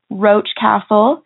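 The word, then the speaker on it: Roch Castle: The three are Penrhyw Hotel, Roach Castle, and Tour of Velen Hotel. Did I get those right? – That's perfect pronunciation.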